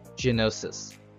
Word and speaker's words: Geonosis: Am I saying that right? Because there's no sh in it. Geonosis. There you go.